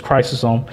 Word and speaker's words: Chrysostom: And in fact, John Chrysostom, John Chrysostom, whichever one you like to go, we'll call the pronunciation is Chrysostom.